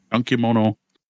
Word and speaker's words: Gunkimono: Gunkimono, we had a way to pronounce this. We looked it up at the time. Gunkimono.